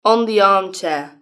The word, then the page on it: in the armchair: British English